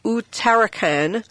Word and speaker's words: Uttarakhand: So this is really interesting that the state of, and I had to look up how to pronounce this, it's Uttarakhand, and it's a northern state in India, and it has awarded animals the same rights as humans in the high court ruling.